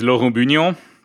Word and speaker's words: Laurent Bugnon: Actually, the French way to say it is Laurent Bugnon, and nobody can say that.